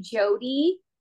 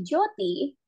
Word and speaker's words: Jyoti: Okay, this is so important to me because I was literally Jyoti until maybe like 2020, and I corrected my name. So my name is actually Jyoti, which is a correct pronunciation.